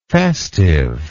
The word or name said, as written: festiveness